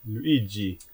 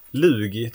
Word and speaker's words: Luigi: Luigi. Ja, hur som helst. Lugi tror jag vi uttalade det förr i tiden. Jag vet inte, vi missade ju en bokstav där men det var ju...